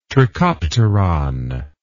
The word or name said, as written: trichopteron